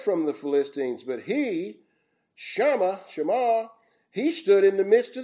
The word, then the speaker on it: Shema: And this man's name was probably pronounced Shema. It looks like Shama, but it's probably pronounced Shema.